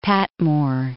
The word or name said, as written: Patmore